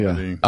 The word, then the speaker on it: Abedin: Is it Abedin? – Yep. – Abedin. Yeah. Abedin, yeah. Good Arabic pronunciation.